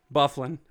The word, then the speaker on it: Byfuglien: The G is silent. Byfuglien.